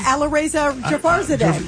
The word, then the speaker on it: Alireza Jafarzadeh: And our friend that represents the Iranian people, Arizani? – Yeah, Alireza Jafarzadeh. Yes, I love Alireza. – You can pronounce his name better than I know, it's a hard name.